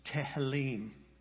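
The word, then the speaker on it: Tehillim (תהלים): And then Psalms. Psalms is pronounced Tehillim. It begins with the Hebrew letter Tav, which is the very last letter of the Hebrew alphabet.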